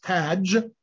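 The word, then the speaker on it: Taj (תאג): I told you it's the word Taj. You can see the word Tav Aleph Gimel. It's pronounced Taj.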